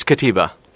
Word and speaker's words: Kutyba: It's Kutyba.